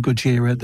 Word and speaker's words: Gojira: Gojira. That's the right pronunciation, Gojira.